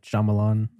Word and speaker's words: Shyamalan: Night Shyamalan. – Oh. That's how you say it, I think.